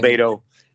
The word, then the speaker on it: LoBaido: LoBaido.